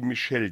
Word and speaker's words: Michel: Wir hören den Rumba d'Amore und einen Sänger, der heißt Michel hier auf dem Titel, Miszell geschrieben, das spricht sich aber im Italienischen französisch aus wie Michel.